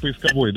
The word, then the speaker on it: поисковой: Да, согласно правилам русского языка, ударение в данном слове всегда, слышите, падает на букву О третьего слога. Так, подождите, подождите. Что сказала дочь? – Дочь сказала, там, по-моему, третий вариант поисковой, да, у нас был?